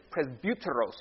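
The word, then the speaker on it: presbyteros (πρεσβύτερος): This has not really much to do with it, but the word for elder is presbyteros. Everybody say presbyteros. Say it like you have a... It sounds like an angry Russian when I say it.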